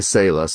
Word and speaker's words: Sala: Is it Sala Wines? – There's a couple of different ways to say it. We say Sala, so it means to pause and reflect.